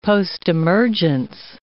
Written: postemergence

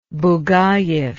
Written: bugayev

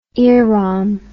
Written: earom

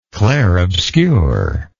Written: clare obscure

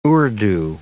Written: Urdu

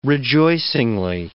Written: rejoicingly

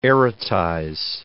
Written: erotize

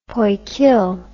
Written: poikil

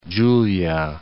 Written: Venezia Giulia

